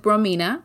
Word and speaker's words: Romina: So you can find me anywhere with Connect with Romina, and my name is spelled R-O-M-I-N-A.